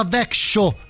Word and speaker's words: Växjö: Il nostro amico Viva Växjö, ecco, si pronuncia così, in Svezia naturalmente, ci ascolta da lì.